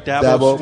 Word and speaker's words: Dabo: And Jerron, what's it like playing for a guy like Dabo Swinney? Am I saying it right? – Dabo Swinney.